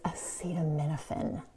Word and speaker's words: acetaminophen: And one more thing, it's acetaminophen.